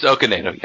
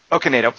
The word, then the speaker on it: Okonedo: In After Earth, at one point, Will Smith comes into is it Sophie Okonedo? Dingus, how do I say her last name? Because I love That's how you say it. It's Okonedo, yeah. – Okonedo.